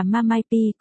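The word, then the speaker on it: ma mai pi (妈卖批): MMP, mụ bán phê, ma mai, phát âm là ma mai pi, có thể viết thành từ viết tắt MMP.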